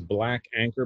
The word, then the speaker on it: BLKANKR: The name of the business is Black Anchor, but it's spelled BLKANKR. Instagram at BLKANKR.